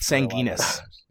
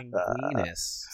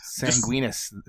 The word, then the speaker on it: Sanguinis: Well, at least it's not Sanguinus. – Yeah. Sanguinis. – Sanguinis. I love that it rhymes with penis.